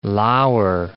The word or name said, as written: lour